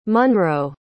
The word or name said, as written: munroe